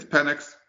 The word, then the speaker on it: Penix: I think he, you know, it's Penix, but for what he's saying, Dave's insistence to mispronounce Michael Penix's name. – Michael Penix's name. – To rhyme with penis is sexist.